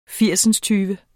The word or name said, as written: firsindstyve